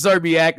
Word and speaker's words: Szczerbiak: It's Zerbiak. That's how you say it.